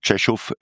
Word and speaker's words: Rzeszów: Ich glaube, das Kriegsgebiet beginnt eigentlich in der Stadt, die wird anders geschrieben, RZESZOW, Rzeszów wird das ausgesprochen, aus Polen.